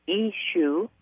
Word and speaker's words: Yshu: This remarkable book tells the whole story as lived by Simon, from the time he met Ishu, that was Jesus' real name, Ishu spelled Y-S-H-U, and pronounced Ishu.